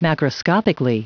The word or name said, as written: macroscopically